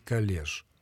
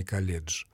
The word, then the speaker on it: колледж: А в принципе, если произносить его на французский манер, мы должны даже говорить «коллеж», а не «колледж». Словарями, орфоэпическими словарями русского языка допускаются два варианта произнесения этого слова.